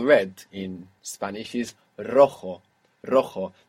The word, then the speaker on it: rojo: This is spelled R-O-J-O. And J's in Spanish are pronounced J, like this. Rojo. – Rojo. – And we have the initial R, so if you can do it, it will be the rolling R. Rojo.